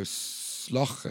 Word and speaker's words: Shough: So it reads Andrew Slough, but I got told that it's supposed to be pronounced as like you pronounce cough. So Sloch, and then it sounds like shelf.